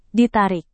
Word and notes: ditarik: Indonesian

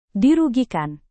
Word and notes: dirugikan: Indonesian

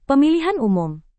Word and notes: pemilihan umum: Indonesian

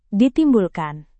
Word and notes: ditimbulkan: Indonesian

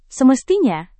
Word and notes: semestinya: indonesian

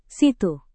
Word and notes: situ: Indonesian

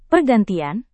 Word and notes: pergantian: Indonesian

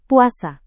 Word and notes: puasa: Indonesian

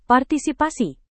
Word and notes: partisipasi: Indonesian

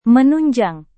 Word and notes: menunjang: Indonesian

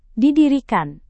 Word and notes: didirikan: indonesian

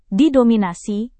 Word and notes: didominasi: Indonesian

